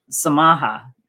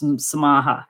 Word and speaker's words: samaha: So, and that comes from forgiveness, which forgiveness in Swahili is samaha. You barely pronounce the M, but it's samaha. And forgive is samahe.